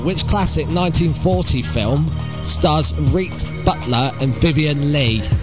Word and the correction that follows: Rhett: What classic 1940 film stars Reet Butler, I believe that's how you say it, Rhett Butler, and Vivian Leigh?